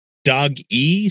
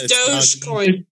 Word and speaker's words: Dogecoin: Dog E, somebody says that. – It's Dogecoin. – Doge.